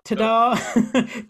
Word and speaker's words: Tado: is it Tado? Tado? – Tada. – T-A-D-O.